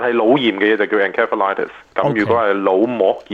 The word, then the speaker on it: encephalitis: 佢用唔係用個 C 音喎，用 S 音係 encephalitis。咁我以前我就自己讀咧係 encephalitis 嘅。咁其實究竟係咪你呢兩個讀音咧，真係有個係正確，一個唔正確嘅咧？ – 都 OK 嘅，其實都冇乜所謂嘅。我哋醫生一般就係讀佢做 encephalitis 嘅。